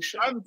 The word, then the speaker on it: Schär: It's spelled Schär with a little umlaut, meaning Schär.